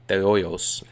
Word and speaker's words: de Hoyos: For Dr. Arturo de Hoyos, de Hoyos, if you're going to say it like a Mexican or like with a Spanish accent, now a faculty member at Brigham Young University, President Kimball is a very special man.